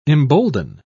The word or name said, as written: embolden